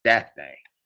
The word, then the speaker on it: Daphnae: It is Taphnas, Taphnas in the Septuagint, but in some secular Greek writings, it's spelled with a D, and it's given a Greek ending, and it's Daphne, D-A-P-H-N-A-E, Daphne. So it's Daphne in secular Greek,